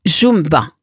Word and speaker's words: Yumba: Son talent se confirmera avec le titre Yumba, en fait qui se prononce Jumba.